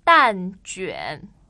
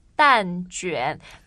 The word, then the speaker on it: 蛋卷: Properly, it should, I think it's 蛋卷. 蛋 meaning eggs. – Right. – 卷 means a roll. So egg rolls. 蛋卷.